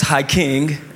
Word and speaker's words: Hykeng: My name is Hyking. You spell that H-Y-K-E-N-G.